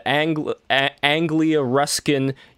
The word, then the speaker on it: Anglia Ruskin: So you are the writer of this book, and you are also senior lecturer at Anglia Ruskin University.